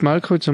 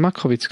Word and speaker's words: Markowitz: Nicht Malkowitz, sondern Markowitz, glaube ich. Was? – Markowitz, doch, habe ich richtig gesagt. Karl ja. Markowitz, genau.